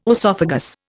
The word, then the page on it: oesophagus: Indian